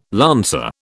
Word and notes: lancer: British English

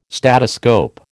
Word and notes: statoscope: American English

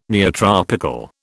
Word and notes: neotropical: British English

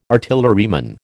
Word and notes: artilleryman: American English

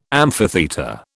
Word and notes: amphitheatre: British English

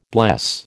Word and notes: bless: American English

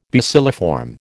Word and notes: bacilliform: American English

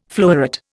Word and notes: floweret: Indian

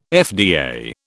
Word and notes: FDA: British English

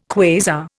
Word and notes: quasar: Indian

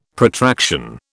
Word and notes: Protraction: British English

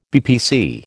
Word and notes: BPC: American English

